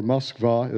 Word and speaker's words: Moskva: It went on for, I don't know how many kilometers, but I got in the middle of these markets in Moscow, and Moscow, or Moskva, as the Russians call it, the proper pronunciation, is a really dark place.